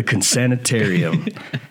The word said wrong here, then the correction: Consortium: Consortium. – Consortium. Native Biodata Consortium.